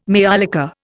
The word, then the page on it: maiolica: Indian